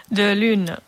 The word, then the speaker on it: De Lune: La Louise, sí? Ho dic bé? – Sí. – Louise de Lune? O sí. De Lune? Com? – De Lune. – De Lune. És clar, és un nom que es pronuncia en francès, oi? – Sí.